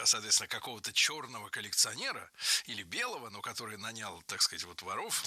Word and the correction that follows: нанял: Нанял. Неверно. Правильно, нанял. Ударение в этом слове падает на первый слог во всех формах прошедшего времени, кроме женского рода. Он нанял, оно наняло, они наняли, но она наняла.